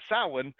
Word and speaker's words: Samhain: It actually is Samhain, or you know, depending on if you're Northern or Southern Irish, and Scots have a bit different.